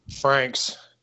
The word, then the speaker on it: Francks: Don Frank. What? I can't pronounce that. Francks. – Franks. – That's a weird way to spell Franks, but I'll go with it.